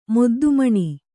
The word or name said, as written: ಮೊದ್ದುಮಣಿ